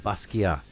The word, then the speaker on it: Basquiat: Is it Jean Michael Basquiat? Oh, Basquiat.